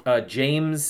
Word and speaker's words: Jeymes: James Samuel. – Oh, it's just James spelled funny? – J-E-Y-M-E-S. – Oh, I guess that does spell James.